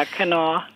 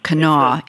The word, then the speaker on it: Kanawha: Yeah, Kanawha. – Kanawha, even though it's one syllable shorter than it looks.